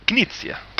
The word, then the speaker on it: Knizia: Bueno, el apellido creo que sí lo pronunciáis. – Knizia. – Knizia.